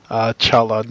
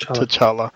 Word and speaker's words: T'Challa: Black Panther states at one point that the T in his name is silent, so it's Chala, not T'Challa, which is never said anywhere, so...